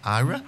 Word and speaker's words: AYRA: A-Y-R-A. – A-Y-R-A. – Ira.